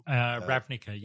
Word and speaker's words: Ravnica: Is that the correct pronunciation? – Ravnica, yep.